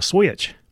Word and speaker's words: switch: I got the hand and the belt and the what they call the switch. You have to say that with almost two syllables to sound Texans, the switch.